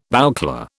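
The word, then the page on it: bouclé: British English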